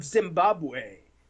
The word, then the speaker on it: Zimbabwe: Zimbabwe.